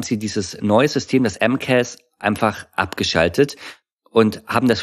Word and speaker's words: MCAS: Also die Abkürzung ist MCAS, heißt das M-Case das oder spricht man jeden Buchstaben einzeln aus? – Nee, ist tatsächlich das MCAS.